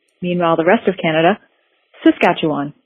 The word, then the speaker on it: Saskatchewan: It's Saskatchewan.